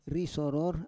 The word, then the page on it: risoror: Roon